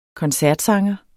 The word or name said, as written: koncertsanger